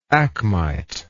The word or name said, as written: acmite